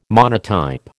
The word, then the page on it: monotype: American English